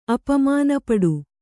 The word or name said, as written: ಅಪಮಾನಪಡು